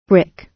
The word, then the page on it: brick: British English